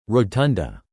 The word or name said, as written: rotunda